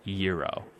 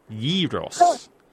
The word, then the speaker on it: gyros: And they spelled it capital Y, capital E, hyphen, lowercase R, lowercase O, lowercase S. Gyros.